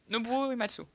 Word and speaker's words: Nobuo Uematsu: Ça voilà, se prononce comme ça, Nobuo Uematsu.